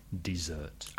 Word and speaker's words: dessert: Desert, desierto, has the stress on the first syllable and one S, desert. But in a restaurant, you ask for dessert with the stress on the second syllable, dessert.